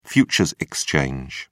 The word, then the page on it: futures exchange: British English